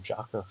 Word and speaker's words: Jaco: I was going to mention it in my awesome things, but I saw, like, Resurrection of F, and Jacko was definitely a highlight of that movie. Or I think they pronounce it Jocko.